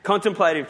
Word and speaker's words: contemplative: Contemplative prayer. Um, can't even say it. Does anyone know how to say it properly? I said it right before. Anyway. – Contemplative. – Thank you.